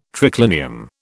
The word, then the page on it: triclinium: British English